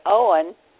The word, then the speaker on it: Eoghan: For those who are new, we should describe that Owen, whose name is spelled E-O-G-H-A-N, did I get that right? – Yes.